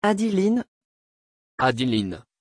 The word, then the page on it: adilyn: French